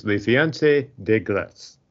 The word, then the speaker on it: Le Fiancé de Glace: But if you look up Snowmance, the first thing Google will tell you is Le Fiancé de Glace, which I really apologize for the French pronunciation there. – It was perfect.